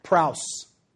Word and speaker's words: praus: And that word that he used there is this word, Greek word right here is pronounced praus.